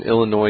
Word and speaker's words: Illinois: Southern Illinois University. – You pronounce the S on Illinois? – Well, while I was transitioning to the U in university, but I'll say it again, Southern Illinois University.